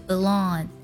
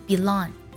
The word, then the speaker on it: belong: 第一个 belong 这个单词啊，我们呢可以把它的第一个音节读作 b 的音，这种现象在美音当中特别常见，读作 belong，也可以读作 belong，都是正确的。